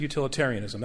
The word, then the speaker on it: utilitarianism: Did I, did I, did I add an extra syllable? Uti- did, I said utilitarianism. That's correct. That's right.